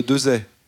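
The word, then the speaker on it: Desaix: Farget avec un X à la fin qu'on ne prononce pas. – C'est bien ça. – C'est comme le général Dezay.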